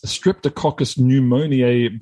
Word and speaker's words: Streptococcus pneumoniae: Now, you're working on this Streptococcus pneumoniae bacterium. Did I pronounce that even close to correct, Megan? – Spot on, actually.